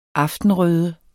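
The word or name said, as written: AFTENRØDE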